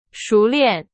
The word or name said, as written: 熟练